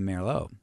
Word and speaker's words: Merlot: Pronouncing the T in Merlot.